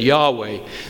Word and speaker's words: Yahweh: Traditionally, we have called the Lord Jehovah, but the scholars are encouraging us to say that Yahweh is probably more accurate.